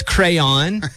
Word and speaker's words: crayon: A crown. – I'll accept it. You said crown, but it's crayon.